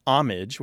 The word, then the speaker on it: homage: So first we have the pronunciation homage, which is sometimes pronounced with the H at the beginning.